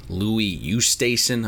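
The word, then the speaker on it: Louis Oosthuizen: I did see Louis Oosthuyzen.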